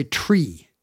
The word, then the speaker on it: Tree: You're not supposed to say three. You're supposed to say tree. Four is officially pronounced four in the International Radio Telephony Spelling Alphabet. It's two syllables. Five is fife.